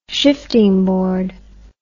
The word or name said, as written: shiftingboard